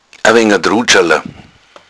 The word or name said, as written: drudschala